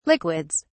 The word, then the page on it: liquids: British English